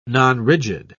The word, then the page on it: nonrigid: British English